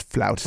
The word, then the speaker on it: flautist: Nowadays, you have to be a classically trained flutist like Lizzo. – I prefer for it to be pronounced flautist.